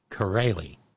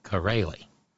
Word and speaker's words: Chiarelli: Corelli. C H I A R E L L I, pronounced Corelli.